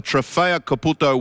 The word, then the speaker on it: Trofeo Caputo: Did I say it right? The Trofeo Caputo World Pizza Championship? – That's right. You did.